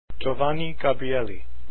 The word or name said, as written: gabrieli